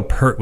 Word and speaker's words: Peart: I think most people, I don't know, I think Neil Peart was kind of a dialectal thing, because we grew up calling him Neil Peart. – Right. – And then, you know, when he passed away and people were pronouncing Peart, I'm like, and then I kind of looked into it and like, yeah, no, we were just been saying it wrong.